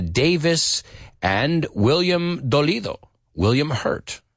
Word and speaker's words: Hurt: Now remember, esa U se pronuncia como si fuera una E. El nombre de este actor, que también significa dolorido. William Hurt. Say it with me. No es heart. Heart es corazón.